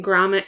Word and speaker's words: Gromit: Wallace and Gromit, is that how you say it? The Were-Rabbit. – Wallace and Gromit, Curse of the Were-Rabbit.